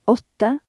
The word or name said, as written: 8